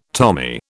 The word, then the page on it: Tommy: British English